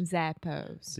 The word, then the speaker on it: Zappos: when we say Zappos, Zappos. – This is a very good example of at least American, North American English pronunciation. We say za, a.